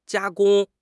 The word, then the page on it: 加工: HSK6